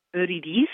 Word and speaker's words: Eurydice: Well, it's confusing because we're doing it in French, so technically it's Orphée et Eurydice, but I've heard Orpheus and Eurydice. – Eurydice, okay. Well, and I've been saying Eurydice. I don't know. – I think it's all, because then, of course, it was originally Italian. So I think there's a lot of acceptable pronunciations.